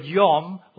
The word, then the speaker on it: yom: Just to finish this matter about days, the Hebrew word yom, Y-O-M, we would say in pronouncing it in English, is used in three particular ways in the opening two chapters.